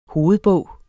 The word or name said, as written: hovedbog